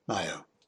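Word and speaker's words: Ngaio: And the second, I learned how to pronounce Nio. – Scales of Justice by Nio Marsh, narrated by Benedict Cumberbatch, is available commercially.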